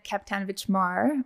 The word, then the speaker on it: Kapetanovic-Marr: My name is Susan Kapitanovich Marr.